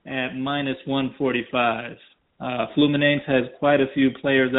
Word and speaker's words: Fluminense: Yes, it is Corinthians versus Fluminense, and I did two plays on that game.